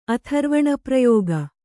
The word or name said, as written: ಅಥರ್ವಣಪ್ರಯೋಗ